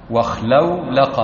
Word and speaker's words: اخلولق: واخلولق، واخلولق، هكذا تنطق. قال: وما وضع للدلالة على الشروع.